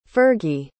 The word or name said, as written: fergie